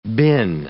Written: bin

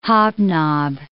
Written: hobnob

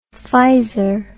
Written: pfizer

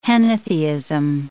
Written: henotheism

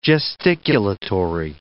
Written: gesticulatory

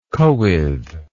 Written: cywydd

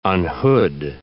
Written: unhood

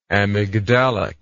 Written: amygdalic

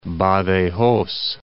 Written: Badajoz

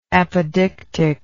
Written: apodeictically

